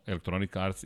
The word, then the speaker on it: Electronic Arts: Da li se sećate Electronic Arca i kako je bilo? To mi on rekao, ako ovo budete spominjali i bude stavio potpis It's in the name, molim ti izgovori kao Electronic Arts It's in the game.